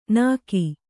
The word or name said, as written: ನಿಕ್ಕಿ